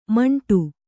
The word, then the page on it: ಮಂಟು: maṇṭu